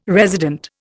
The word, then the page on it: resident: Indian